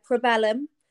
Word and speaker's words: Probellum: First thing I'm going to ask you, how do I pronounce it? Is it Probellum or Probellum? How am I pronouncing this? – Yeah, Probellum. – Right, Probellum, okay.